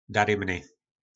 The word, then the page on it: darymny: po našimu